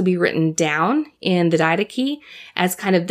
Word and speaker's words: Didache: It's from the Didache, and the Didache is, if you go on Wikipedia, you can look it up, and it's not spelled like how it sounds. It's actually spelled like D-I-D-A-C-H-E, so it's spelled like did ache, but it's pronounced Didache.